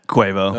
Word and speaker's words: Quavo: Quavo.